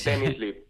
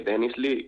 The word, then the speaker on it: Denizli: Denizli, llámale Denizli, que es el equipo de la ciudad donde es el equipo, así que Denizli y más fácil.